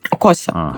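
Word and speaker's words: Xhosa: Вообще название племени, название языка произносится именно с таким цокающим звуком. То есть коса. Я не могу произнести одновременно цоканье и звук «к», но они могут, и спасибо им большое за это.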